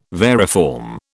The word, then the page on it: variform: British English